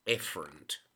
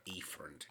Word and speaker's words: efferent: And E is purely efferent, or efferent, if you like that uh, pronunciation.